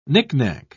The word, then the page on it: nicknack: British English